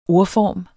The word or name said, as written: ordform